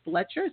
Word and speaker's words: Blecher: I'm excited about our next guest with Kate Blecher.